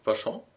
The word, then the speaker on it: Vachon: Wie spricht man es aus? – Vachon.